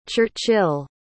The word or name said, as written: churchyll